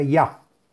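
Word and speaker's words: Ya (יה): Y es el santo nombre de Yud Hei y se pronuncia Ya y dice la página, la oración frecuente del Salmo número 79 se dice que es fatal para los enemigos y oponentes.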